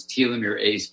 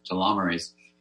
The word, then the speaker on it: telomerase: So it's telomere ACE, but we pronounce it telomerase.